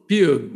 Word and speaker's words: Pugh: no sé cómo se pronuncia el apellido, porque lo escuché en varios lados y todo el mundo lo pronuncia distinto, Florence Pugh, o Florence Pugh, o Florence, como quieran llamarlo. – Pugh. – Bueno, Florence Pugh.